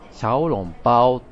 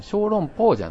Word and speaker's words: 小籠包: で、まあ、こっちの発音だと、シャオロンパオって言うんだよね。うん、小籠包じゃないんだよね。